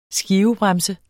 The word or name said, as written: skivebremse